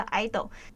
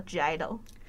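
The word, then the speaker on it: (G)I-DLE: 就是大家都知道那个 G I-DLE 那个 G 有个括号嘛，但那个括号其实是发音省略的意思，所以它读音其实叫 idol。但是呢，使用非韩语时 G 会发音，所以还是叫 G I-DLE。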